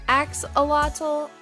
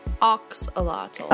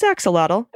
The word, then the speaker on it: axolotl: In English, it's commonly pronounced axolotl and oxolotl. – Oh, it's axolotl.